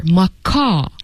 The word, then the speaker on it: macaw: So you really should pay attention that you don't pronounce the W here, macaw.